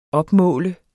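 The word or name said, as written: OPMÅL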